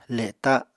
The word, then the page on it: le7ta7: apatani